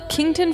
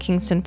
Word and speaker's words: Kington: Kington Fort, and I'm going to say this spelled K-I-N-G-T-O-N. I have to spell it out because I kept wanting to call it Kingston Fort because it's Davis County.